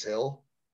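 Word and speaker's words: Gil: That's how I pronounce it. I know people pronounce it different ways. It's spelled G-I-L. I think it's Luis Hill. Kind of just roll into it.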